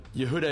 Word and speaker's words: Yehuda: So Yehuda is Y-E-H-U-D-A, as if my first name. Stern is S-T-E-R-N, Rabbi Yehuda Stern.